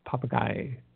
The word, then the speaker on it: Popigai: Papagai, I guess it's pronounced Papagai, P-O-P-I-G-A-I, crater.